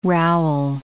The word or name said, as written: rowel